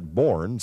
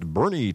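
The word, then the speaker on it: Boerne: On 1500 KBRN in Burney, Texas, a lot of people tend to pronounce that Bourne, since that's the way it's spelled, but it's pronounced Burney, Texas.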